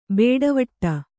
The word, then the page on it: ಬೇಡವಟ್ಟ: bēḍavaṭṭa